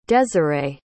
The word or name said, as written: desiree